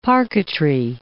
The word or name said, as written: parquetry